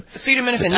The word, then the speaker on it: acetaminophen: You might take some, what is it, acetaminophen? Is that what they call that? I never can pronounce that correctly. But acetaminophen, yeah, that's Tylenol.